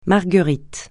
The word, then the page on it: marguerite: francais